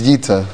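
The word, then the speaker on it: Yita (איטא): It's pronounced Yita. It's spelled Aleph Yud Tes Aleph, but you should have Refu Shalema and come back Gesundheit.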